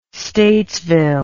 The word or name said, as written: statesville